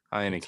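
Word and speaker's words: Heinicke: How do you say this young man's last name? – Heineke. – Again? Heineke.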